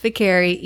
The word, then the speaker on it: Vicari: And so then I was like, nope, I said it wrong. I need to say it right. It's Vicari, even if it's more challenging for people.